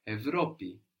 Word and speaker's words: Ευρώπη: To see an example of it pronounced like V, we said this is less common, but it still happens. We have Europe, Ευρώπη, Ευρώπη.